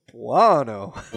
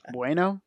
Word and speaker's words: buono: Because he goes, buono. – Buono. – Bueno? – No, he doesn't say bueno. – Buono. – Because I was like, is that how you say it? And then, because bueno is Spanish.